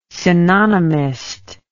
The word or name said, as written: synonymist